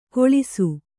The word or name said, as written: ಘೋಳಿಸು